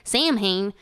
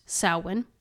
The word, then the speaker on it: Samhain: to, I because they thank God they put it in there because I'd be like Samhain. – I uh, would have corrected. It says you. – pronounced Samhain.